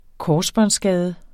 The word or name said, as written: korsbåndsskade